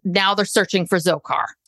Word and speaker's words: Dzhokhar: and then how do you pr- pronounce the younger brother's Dzhokhar? Dzhokhar, because the D is silent.